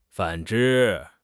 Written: 反之

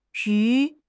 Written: yu2

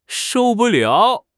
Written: shou4 bu4 liao3

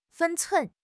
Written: 分寸

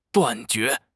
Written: 断绝

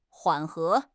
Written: 缓和

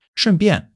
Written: shun4 bian4

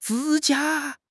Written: 指甲

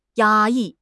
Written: 压抑

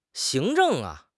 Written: 行政

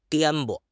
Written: 颠簸

